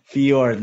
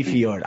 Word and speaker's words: Fjord: Your last name, is it pronounced Ford or Fjord? – Fjord. Yeah. – Oh, no. Okay. Fjord, Lee Fjord.